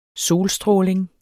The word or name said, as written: solstråling